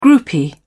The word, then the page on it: groupie: British English